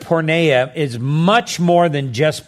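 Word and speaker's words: porneia (πορνεία): Now you must understand that the term here for immorality is a Greek term uh, that we um, uh, that is pronounced porneia.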